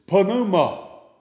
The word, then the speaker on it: pneuma: So if you see that P-N-E-U, it's pneuma. You have to pronounce all the letters.